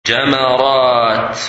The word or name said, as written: Jamarat